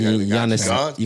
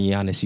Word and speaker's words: Giannis: Now that guy look like Giannis. You gotta call him Giannis. You can't. If you're gonna say his name, Giannis Antetokounmpo.